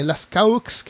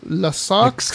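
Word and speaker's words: Lascaux: Hay una caverna en Francia que se llama eh, Las Caux, que se debe pronunciar algo así como Las Saux. Las Saux o algo así.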